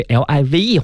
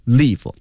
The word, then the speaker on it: live: 我们不仅呢要学习啊这个 transmitted 表示的意思是传送信号，咱们还要了解这个句子当中 transmitted live，大家把这 live 化下来啊，它不要读成 live，它读成 live。